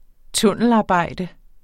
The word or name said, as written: tunnelarbejde